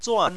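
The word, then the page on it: zuan: tone 4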